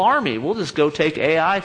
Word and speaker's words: Ai: The next city is Ai, one of the, you know, shortest city names ever. Ai. And that's how you say it. Ai.